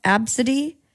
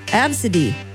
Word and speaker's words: Abcde: super shocked that a parent named their child A, B, C, D, E, but it's pronounced Absidy.